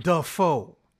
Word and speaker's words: Dafoe: Dafoe.